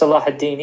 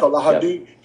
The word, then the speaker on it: Salahuddin: but his name is Saladin or Saladin. – Yeah, it's Salahuddin, yeah. – Salahuddin.